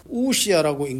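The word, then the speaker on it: 우시아 (οὐσία): OUSIA, 우시아라고 읽는데 이게 존재예요.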